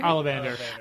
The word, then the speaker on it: Ollivander: Ollivander? – Ollivander.